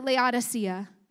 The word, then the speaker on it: Laodicea: Well, when you get to the last letter in Revelation chapter 3, we get to this church, to the church in Laodicea. Some pronounce it Laodicea. To the church in Laodicea, Jesus writes.